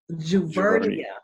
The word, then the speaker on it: Juvernia: Juvernia.